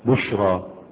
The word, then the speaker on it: بشرى: بشرى، اكتبها إيه؟ تنطق ألفا، تنطق ألف ولكن تكتب ياء قبلها مفتوح.